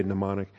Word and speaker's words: mnemonic: It's a silent M. You're not supposed to pronounce it. You're supposed to just say mnemonic.